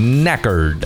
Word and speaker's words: knackered: Knackered. – Knackered. – Knackered. – Knackered. – Wait a minute. See if Windsor's got it. Silent. – Here. – The K is silent.